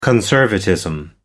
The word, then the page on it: conservatism: American English